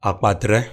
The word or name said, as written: Akpatre